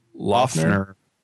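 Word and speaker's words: Loughner: Laughner.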